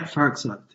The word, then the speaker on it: Farrokhzad: Yeah, Farrokhzad, yeah.